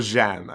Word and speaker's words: Jeanne: Or Jeanne, I suppose, which is the French pronunciation.